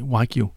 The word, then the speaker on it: Wagyu: Ja, men så er det ikke ligesom den der Wagyu-kød, hedder det ikke det, hvor der er rigtig meget Wagyu. – Fedt i? Wagyu. – Wagyu. – Hedder det? – Ja. – Wagyu-kød. Nå, jeg har udtalt forkert.